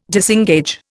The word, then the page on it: disengage: Indian